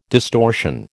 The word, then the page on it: distortion: American English